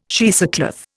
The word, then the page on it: cheesecloth: Indian